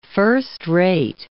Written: first-rate

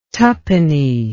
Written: tuppenny

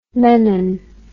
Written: mennen

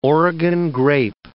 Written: Oregon grape

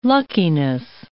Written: luckiness

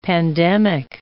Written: pandemic

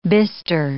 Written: bister

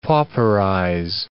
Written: pauperize

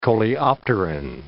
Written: coleopteran

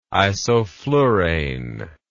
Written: isoflurane

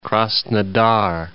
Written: Krasnodar

